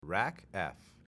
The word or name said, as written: RACF